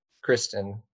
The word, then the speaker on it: Kristin: It's an I N, Andrew, Kristen, with two I's. The vowels make the same sound on each syllable. And I was like, oh, yeah, of course, K R I S T I N.